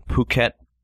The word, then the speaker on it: Phuket: The Phuket and Pho thing. Mm-hmm. The French were the colonizing power in that area, and that's why it's spelled fuck it for us, but it's pronounced Phuket.